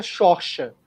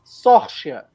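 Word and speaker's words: Saoirse: É, a pronúncia correta é Xorcha. Não, velho. Sorcha. Eu juro.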